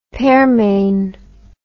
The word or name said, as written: pearmain